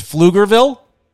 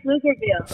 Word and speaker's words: Pflugerville: I'm presuming the P is silent, so it's Pflugerville? – That's correct, Pflugerville.